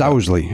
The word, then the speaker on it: Owsley: It's Owsley. Owsley. – Owsley. – Owsley. – That's tough. No matter what, can agree that that's tough spelling and pronunciation. – Yes, he made a mistake. – I wanted him to be Owlsley.